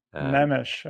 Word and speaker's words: Nemes: Nemes, okay. – Nemes, yeah.